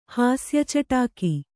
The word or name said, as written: ಹಾಸ್ಯಚಟಾಕಿ